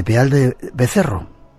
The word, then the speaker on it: Peal de Becerro: Eres arqueóloga, te licenciaste en la Universidad de Jaén y eres la responsable del Centro de Interpretación de Peal de Becerro.